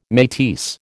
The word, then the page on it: metis: American English